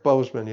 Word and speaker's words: Boseman: Is it Boseman? Is that how it's pronounced? – Chadwick Boseman, yeah.